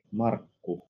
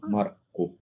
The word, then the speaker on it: Markku: It does, but we kind of swallow it at the end. Yeah, we don't say the long U, but we say Markku.